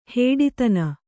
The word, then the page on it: ಹುಟ್ಟುತನ: huṭṭutana